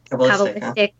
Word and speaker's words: Kabbalistic: it's the Kabbalistic, is that how you say it? – Kabbalistic.